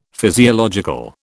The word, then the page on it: physiological: British English